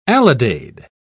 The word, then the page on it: alidade: British English